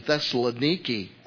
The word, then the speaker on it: Thessaloniki: And here Paul is writing to this great church in Thessaloniki, as it is pronounced today.